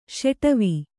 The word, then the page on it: ಷಾಡವ: ṣāḍava